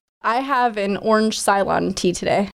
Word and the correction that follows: Ceylon: How do you say it? – I believe it's Ceylon.